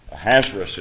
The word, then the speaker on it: Ahasuerus: there's some discussion as to how to pronounce this king's name. Ahasuerus is one of the ways that some believe that the name should be pronounced, and that's the easiest for me, so that's how I'm going to pronounce his name.